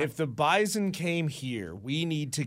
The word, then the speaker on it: Bison: I'm told it's like, you know, they're very particular about the pronunciation. – Correct. – It's Z. – Bison. – Bison.